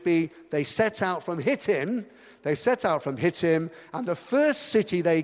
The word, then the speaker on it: Shittim: It's actually spelled S-H-I-T-T-I-M, but it's pronounced Hittim. It's just like in Spanish, you say, yo hablo, hablo, yo hablo español, or yo hablo. You drop the H because it's silent. And this is Hittim, it's not with the S.